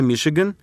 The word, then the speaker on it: Мичиган: Мичиган, произносится Мишиган, это 2 полуострова, стиснувшие великие озера с севера и юга.